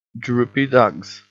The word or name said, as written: droopy dugs